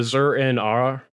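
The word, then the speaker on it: Zur-En-Arrh: The colorways I like at the bottom that you get, the Zur-En-Arr, that's how I've always pronounced it.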